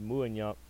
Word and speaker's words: Muyumba: Tristan Mumbaba or Muyumba. That's how you say his name.